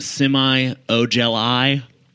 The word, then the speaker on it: Semi Ojeleye: And I think it's pronounced semi Ojeleye.